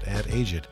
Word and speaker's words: AdAged: Yes, that's how you pronounce it, AdAged.